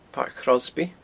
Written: Pairc Chrosby

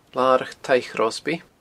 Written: Larach Taigh Chrosby

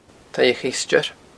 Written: Taigh a Cheisteir

